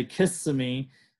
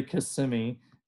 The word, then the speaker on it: Kissimmee: Yeah, so a lot of people say Kissimmee, but it's really Kissimmee. So it's kind of the end of that, those S's, that's really kind of like emphasizes the syllable and whatnot.